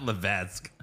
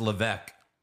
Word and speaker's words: Levesque: Not Levesque. Whatever. You don't pronounce the S. It's just Levesque.